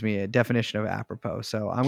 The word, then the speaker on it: apropos: I don't. That's how you say it, apropos.